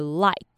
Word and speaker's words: light: 第二个，对于双母音 I，像是那个眼睛的 eye 或是 light。我的诀窍呢，就是念 a 的时候不能用很平白的 a，发音位置呢要在口腔后面一点，念成 a，然后尾音一定要收到 e 的音，所以念成 i light。